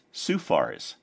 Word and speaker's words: SUHFARS: a member of the SUHFARS, pronounced SUFARS, the Suburban UHF Amateur Radio Society of Chicago.